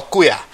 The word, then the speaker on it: kuya: And then for the same equivalent, male in your generation, you call that are older, you call kuya, KUYA. So if you hang around with me or see any Filipinos and they call someone like kuya,